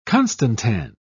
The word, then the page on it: constantan: British English